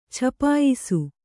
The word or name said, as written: ಛಪಾಯಿಸು